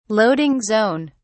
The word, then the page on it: loading zone: British English